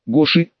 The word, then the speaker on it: Гоши: Гоши, ударение на «и», делился мудростью, да и лис, нет-нет, да подсказывала что-то полезное.